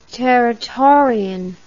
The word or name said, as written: territorian